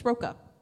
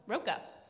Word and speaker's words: Rocha: I'm going to rec is the Beyond series by Kit Rocha, which or Rocha? I never know how to say it's, I think it's Roca. – Roca. Well, yeah. I did it wrong twice.